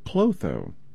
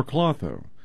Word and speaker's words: Klotho: It's called Clotho, or Clotho. It's spelled K-L-O-T-H-O.